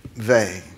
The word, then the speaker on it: Weil: Weil. W-E-I-L.